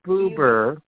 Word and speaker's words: Buber: Yeah, Martin Buber